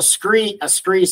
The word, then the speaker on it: scree: And so a SKRE slide is just another name for rock slide. And so and it's actually spelled S-C-R-E-E, but the pronunciation is S-K-R-E, and we landed on that as our brand name.